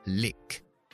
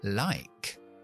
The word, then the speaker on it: like: Si yo quito la E, yo como nativo lo leo, voy a pronunciar lick, lick. Al añadir la E mágica, hace que la vocal se pronuncie por su nombre, es decir, como en el abecedario. Así que lick se convierte en lie, like. O por ejemplo, hornear, que es bake.